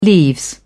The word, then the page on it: leaves: British English